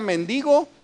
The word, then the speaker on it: mendigo: Una vez yo dije aquí que hay palabras que se escriben igual pero se pronuncian diferente, y les puse un ejemplo, la palabra mendigo y méndigo.